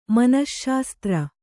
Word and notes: ಮನಶ್ಶಾಸ್ತ್ರ: manaśśastra

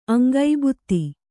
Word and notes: ಅಂಗಭಿತ್ತಿ: aŋgabhitti

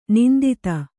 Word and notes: ನಿಂದಿತ: nindita

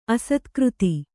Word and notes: ಅಸ್ತಗತಿ: Kannada

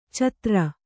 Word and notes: ಛಾದರು: kannada

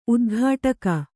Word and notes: ಉದ್ಘಾಟಕಿ: udghāṭaki

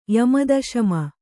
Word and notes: ಯಮದಶಮ: yama daśama

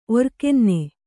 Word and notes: ಒರ್ಕೆನ್ನೆ: kannada; orkenne

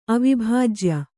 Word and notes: ಅವಿಭಾಜ್ಯ: avibhājya

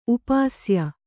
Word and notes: ಉಪಶಾಯ: upa śaya

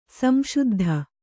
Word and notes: ಸಂಸಿದ್ಧ: samsiddha

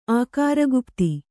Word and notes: ಆಕಾರಗುಪ್ತಿ: ākāragupti; Kannada

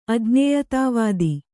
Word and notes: ಅಜ್ಞೇಯತಾವಾದಿ: Kannada; ajñēyatāvādi

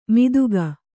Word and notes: ಮಿದುಗ: kannada

